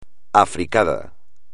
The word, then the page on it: africada: espagnol